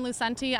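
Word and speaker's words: Lucente: Hi, my name is Lauren Lucenti. I'm a fourth-year psychiatry resident. That's spelled L-A-U-R-E-N-L-U-C-E-N-T-E.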